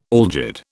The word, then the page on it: algid: British English